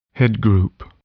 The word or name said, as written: headgroup